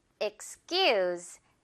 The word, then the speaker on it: excuse: 자, 그럼 먼저 첫 번째 단어, excuse. Excuse me 기억하면 되는 단어죠. 앞부분 x 이렇게 시작한다는 거 x 해주고 excuse 길게 끌어주면 됩니다.